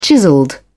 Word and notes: chiselled: British English

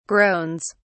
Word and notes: groans: British English

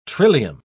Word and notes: trillium: British English

